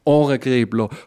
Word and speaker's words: Ohregribler: 50 Jahre Ohrengräbler. 50 Jahre originell und original. Betonung ist ja auf dem Ohr, oder? Genau.